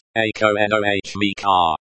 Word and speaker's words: Экономика: The stress is on the second syllable, no. Think of it as ekonomika.